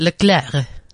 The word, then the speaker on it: Leclerc: Well, because it's French, you like drop the C, don't you? – Well, first tell me. – So it's Leclerc. – How's it spelled? – L-E-C-L-E-R-C. – How do you pronounce it? – Leclerc. – Leclerc.